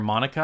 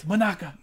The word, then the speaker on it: Manaka: But then in the English dub, they kept calling her Monica. I'm like, her name's not Monica. It's Manaka.